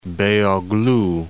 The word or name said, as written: Beyoglu